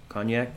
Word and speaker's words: Cognac: Cognac, is that how you say it? Cognac? – Cognac.